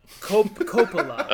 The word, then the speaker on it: Coppola: Is it Corleone and Coppolona? – Coppola. Coppola.